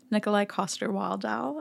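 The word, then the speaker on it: Nikolaj Coster-Waldau: Please trust me that I look up everyone's pronunciation name, and he says it really fast in every interview, so it's hard to get it perfect. But Nikolai Coster-Waldau, I think.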